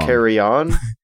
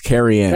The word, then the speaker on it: Carrion: Carrion. I was about to say it wrong. – Carry on? – No, it's Carrion. – Carry on my wayward son. – It's Carrion. Yeah, I had to check a double.